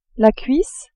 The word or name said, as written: cuisse